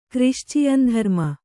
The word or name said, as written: ಕ್ರಿಶ್ಚಿಯನ್‍ಧರ್ಮ